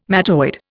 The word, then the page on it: mattoid: Indian